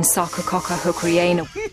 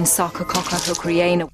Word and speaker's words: Sarcococca hookeriana: The evergreen Sarcococca hookeriana... The evergreen Sarcococca hookeriana...